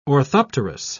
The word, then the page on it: orthopterous: British English